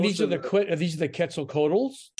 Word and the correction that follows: Quetzalcoatlus: It's pronounced Quetzalcoatlus, not Quetzalcoatls.